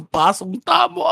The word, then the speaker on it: Pasong Tamo: Mas mali pa yung pagkakapronounce sa pasong tamo. Ang puta. Ano pala? – Pasong tamo. Pasong tamo, pare.